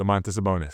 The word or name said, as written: Domantas Sabonis